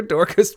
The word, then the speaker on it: Dorcas: Did you say Dr. Dorcas? – Yeah. How would you say his name? – No, I agree with you. That's the way I said it. Dr. Dorcas Malorcas.